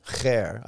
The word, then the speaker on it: Khair: Khair. I think that's probably easier for me. That sort of guttural KH sound is easy.